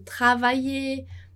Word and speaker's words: travailler: Por exemplo, travailler. A gente viu os verbos arriver, aimer, então todo esse er no francês, ali para os verbos, ele é pronunciado e, né? Travailler, arriver, aimer.